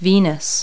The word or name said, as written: venus